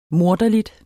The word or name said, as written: morderlig